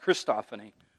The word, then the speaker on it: Christophany: A Christophany or Christophany. It's usually pronounced Christophany.